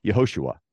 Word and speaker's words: Yehoshua: So earlier in the biblical narrative, Joshua is pronounced as, is written in Hebrew and pronounced in Hebrew as Yehoshua.